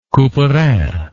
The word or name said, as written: Couperin